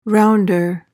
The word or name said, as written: rounder